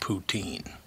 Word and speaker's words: Putin: By the way, apparently in France, Putin is pronounced poutine.